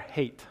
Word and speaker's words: het: So each of these eight verses begins with the word or the letter het, is how we pronounce it. It's like an H.